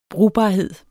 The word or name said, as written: brugbarhed